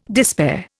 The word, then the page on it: despair: Indian